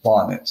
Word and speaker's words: Bonnet: Charles Bonnet syndrome, that's French. It was first documented in 1760 by Charles Bonnet. And the English pronunciation is Charles Bonnet. So if you want to Google this, Charles Bonnet syndrome is B-O-N-N-E-T.